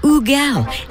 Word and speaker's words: Hugel: His name is spelled H-U-G-E-L. I would automatically go to call him Hugel, which he's fine with. Technically, the correct pronunciation is Ugel.